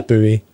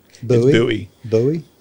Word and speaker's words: Bowie: I think that is how it's pronounced. I know. Everyone else is wrong. It's Bowie. – Bowie.